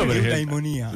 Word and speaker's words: Eudaimonia: Ok, lo vedi come lo pronuncia? Anche con gli accenti giusti. – Eudaimonia.